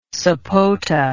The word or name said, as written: sapota